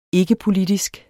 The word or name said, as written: ikkepolitisk